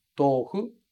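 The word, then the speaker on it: tōfu: Em português a gente fala tofu, mas em japonês a pronúncia é um pouquinho diferente. Tofu, tofu, cuidando para esse hu no final ficar com aquele som todo que eu expliquei para você um pouco mais cedo, ok? Outra coisa importante de falar do tofu é que a gente escreve tou, mas a gente fala to, tofu.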